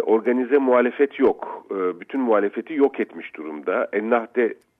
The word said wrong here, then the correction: Ennahda: Partisi, ismini yanlış telaffuz ediyorum. – Ennahda, evet.